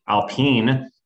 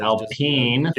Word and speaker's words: Alpine: And like you said, I mean, Severson signing that deal with Alpine. Mr. Ocon has really hasn't had a good race since, honestly. – 13 episodes. Ian pronounces Alpine correctly. – Like Alpine.